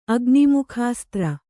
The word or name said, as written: ಅಗ್ನಿಮುಖಾಸ್ತ್ರ